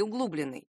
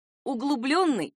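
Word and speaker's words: углублённый: Ни в коем случае нельзя произносить их как облегченный и углубленный. Это такая же ошибка, как осужденный вместо осужденный. Правильные варианты — облегченный и углубленный.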